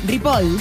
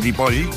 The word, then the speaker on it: Ripoll: Ripoll. – Ripoll. – Ripoll.